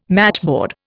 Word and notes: matchboard: Indian

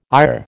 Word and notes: IR: American English

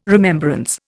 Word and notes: remembrance: Indian

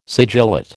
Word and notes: sigillate: American English

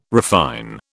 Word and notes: refine: British English

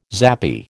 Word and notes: zappy: American English

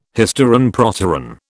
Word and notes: hysteron proteron: British English

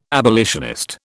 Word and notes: abolitionist: British English

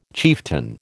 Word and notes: chieftain: American English

